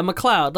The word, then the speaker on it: McLeod: I just realized I don't know how to say his McLeod. – Name. – McLeod. – McLeod, right? – Yeah, McLeod. Like Fox McLeod.